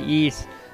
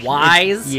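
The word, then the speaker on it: Ys: I said it right, Yeats. It's not Weiss, it's not Yeats or Wise. – Yeats. Yeats. It's Yeats.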